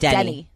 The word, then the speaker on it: Denny: Anyway, we have Denny Love here, who is an R&B singer. You can check him out on Twitter at Denny Love Music. – Denny Love or Danny Love? – Denny. Oh, Denny. Denny Love Music, yeah. – Okay. – D-N-N-Y. Spell it. – D-E-N-N-Y.